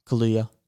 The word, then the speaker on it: Kaluuya: And the nominees were Sacha Baron Cohen, Leslie Odom Jr., Paul Raci, and Lakeith Stanfield, and Daniel Kaluuya. – Mm. – And the winner was Daniel Kaluuya. – Yeah. – Is it Kaluuya or Kaluuya? – Kaluuya.